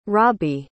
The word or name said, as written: robby